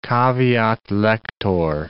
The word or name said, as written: caveat lector